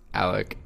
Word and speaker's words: Alec: It's just my name, Alec Corkle. And then I have a Vin. – Can you spell that? I have a trouble with usernames. – It's Alec, A-L-E-C, Corkle, C-O-R-K-I-L-L.